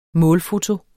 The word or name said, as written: målfoto